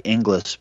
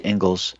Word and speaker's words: Ingalls: And Gatto was citing, I wanted to mention, Alexander James Ingalls, which, as he mentions in the interview, it looks like Inglis, but it's pronounced Ingalls, Principles of Secondary Education.